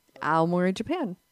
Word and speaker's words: Aomori: Aomori in Japan. Aomori, Japan.